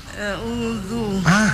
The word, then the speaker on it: A'udzu: Baru berikut a'udzu. – A'udzu. – Bukan zu tapi dzadzu. Zu. – A'udzu.